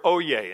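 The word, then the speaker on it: oyez: The threefold use of the word oye, and even though it's pronounced, it's spelled oyes with a Z, it's oye.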